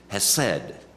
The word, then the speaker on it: hesed: The Hebrew word is hesed. Hesed. H-E-S-E-D in our types of, and there's a guttural pronunciation on the H.